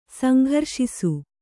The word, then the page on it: ಸಂಘರ್ಷಿಸು: kannada